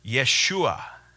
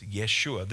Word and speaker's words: Yeshua: And those who came with Zerubbabel were Yeshua. That's a Jewish or Hebrew pronunciation, a Hebrew name, Jeshua, for the name Joshua or the name Jesus, Yeshua.